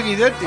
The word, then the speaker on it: Guidetti: Martín dice que si el apellido Guidetti se pronuncia en italiano es Guidetti.